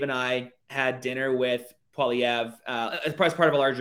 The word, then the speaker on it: Poilievre: Pierre Poilievre.